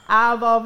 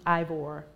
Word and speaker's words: Ivor: The way you say it. Aval, Virginia. – Okay, thank you. – Mm-hmm. Ivor. – Ivor. Yeah. That's how you spell it, y'all. – I-V-O-R.